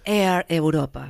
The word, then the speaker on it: Air Europa: Pronuncien como ustedes quieran. Yo le pronuncio acá en español. – Air. ¿Cómo es que conoce y llama bien? – Air Europa. – Bueno, ahí está. Air, querido. Claro.